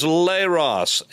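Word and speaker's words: leros (λῆρος): The word is leros, L E, long E is in they, L E R O S.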